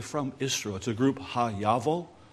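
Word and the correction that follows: HaYovel: It's not pronounced exactly correct. Actually, it's Hey Oval. Hey Oval.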